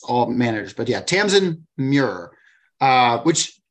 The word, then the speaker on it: Muir: Because we have Gideon the Knight by Tamsin Muir. How would you say that? – Muir is, that's how I would say it. I had a teacher named Muir back in the day.